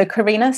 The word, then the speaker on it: Corrina: So Corrina,